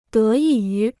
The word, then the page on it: 得益于: dé yì yú